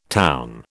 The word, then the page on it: town: British English